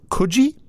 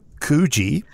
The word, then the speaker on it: Coogee: He went to Coogee, or as they called it in the video, Coogee. – I know. Coogee is a very WA way of pronouncing it.